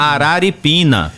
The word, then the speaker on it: Araripina: Aripirina, mas o nome Aripina. É Araripina, Pernambuco.